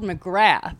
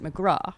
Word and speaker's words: McGrath: It's spelled McGrath, as in Mark McGrath. – Oh, Sugar Ray. – Sugar Ray. I'm on message. But in Ireland... – Okay, with dark hair here? – Yeah. Yes, sir. In Ireland, where she is from, you pronounce it McGrath.